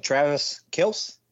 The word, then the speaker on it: Travis Kelce: I think Travis Kelce, is that how you pronounce it? – Yes.